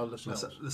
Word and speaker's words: Lascelles: Is it is it Lassels, uh, the centre back, the one that they got from Forest? Uh, oh, Lascelles.